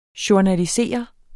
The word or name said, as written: journalisere